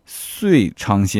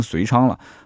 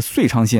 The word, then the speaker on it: 遂昌: 然后还有就是之前有一个读音也错了啊，也错了，但是我看到很多人没有提醒我，但是浙江的丽水是有提醒的，那就是呃浙江丽水下面的遂昌县。它其实是半身不遂的遂啊，所以我那期节目读成遂昌了。后来呢，我那天晚上刚录完节目，打开电视机，电视机在播新闻，说，呃，我们遂昌县什么什么什么什么。哎，我说怎么是遂昌呢？